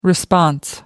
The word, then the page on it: response: American English